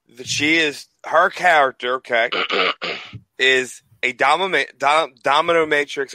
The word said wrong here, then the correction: dominatrix: or how you pronounce that? – Dominatrix.